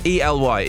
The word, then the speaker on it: Ely: Jeffers from, I can never pronounce this right. ELY. Is it Ellie or Eli? – Ely.